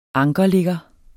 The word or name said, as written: ankerligger